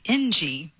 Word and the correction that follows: Engie: but I've said that twice now. – Right. – Both times you corrected me. It's Engie. – Engie. Got it. – Yes, Polo. – I just thought you were going to say B-I-N-G-O.